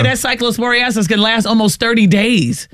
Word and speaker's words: cyclosporiasis: That cyclosporiasis. – That's how you say it. – Ain't it cyclosporiasis? – Yeah, I think so.